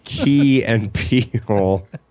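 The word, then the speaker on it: Key and Peele: I was watching the Comedy Central the other day, and this P and Keel came on. – Anyways, Key and Peel. – Yes. – Did I say it right? – Yes, yep. You got it.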